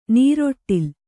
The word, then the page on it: ನೀರೊಟ್ಟಿಲ್: nīroṭṭil